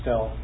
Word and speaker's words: stele: At an article where there's a stele found cracked. – Stele or stele also. That's another. You can pronounce it either way.